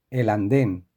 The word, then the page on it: el anden: Spain